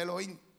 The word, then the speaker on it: Elohim: Se dice Elohim.